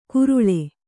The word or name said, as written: ಗುರುಳೆ